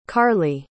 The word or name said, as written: karlee